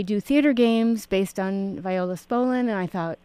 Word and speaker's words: Viola Spolin: I'll tell you a little backstory, how I heard about Viola Spolin.